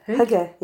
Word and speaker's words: hygge: And so does that contribute to the hygge? I don't know how to say it. – Hygge.